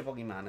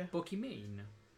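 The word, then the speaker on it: Pokimane: Non si dice Pokémon, attenzione, si dice Pokémon.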